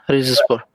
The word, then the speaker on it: Rizespor: Yeah, Rizespor.